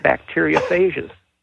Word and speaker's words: bacteriophage: which is bacteriophages. – Bacteria what? – Bacteria, well, it depends on whether you use European vowel sounds. A phage, P-H-A-G-E.